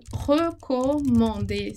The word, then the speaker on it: recommande: A gente vai falar recommande, recommander. Esse E primeiro ele não vai ter som, então ele vai ser mudo e ele vai ser pronunciado ali bem fechadinho.